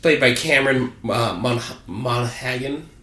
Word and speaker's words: Monaghan: Yeah, Monaghan. – So for those of you who watch Gotham... – You gotta get your, like, kind of Gaelic, Scottish, Irish pronunciation.